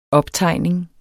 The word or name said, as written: optegning